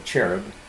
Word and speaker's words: cherub: It goes on to say in verse 14, you were the anointed cherub who covers, or cherub, usually pronounced with a hard K sound for the CH in the beginning of the word.